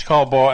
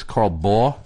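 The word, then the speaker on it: Baugh: Do you know how to pronounce that name? – Yeah, it's Carl Bau, and the answer is no, they're not affiliated. – That's Carl Bau? – Yeah, Bau.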